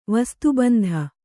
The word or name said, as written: ವಸ್ತುಬಂಧ